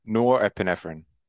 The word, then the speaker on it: norepinephrine: Norepinephrine. Am I on the right track with norepinephrine? I'm pretty sure that's how you pronounce it. Essentially adrenaline. Essentially norepinephrine. – Norepinephrine.